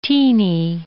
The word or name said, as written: teeny